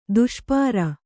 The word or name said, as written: ದುಷ್ಪಾರ